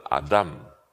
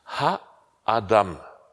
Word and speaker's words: Adam: Das heißt nämlich nicht Adam, sondern Adam. Adam spricht man es aus. Ha-Adam steht dort in der Bibel. Man betont auf der zweiten Silbe, wie auch im Arabischen und da in den semitischen Sprachen, Adam. Ha-Adam.